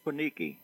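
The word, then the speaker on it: Lajkoniki: I always mispronounce it, but it's Lakonichi. Is that, am I doing it right? – Lakoniki.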